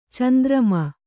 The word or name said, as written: ಚಂದ್ರಮ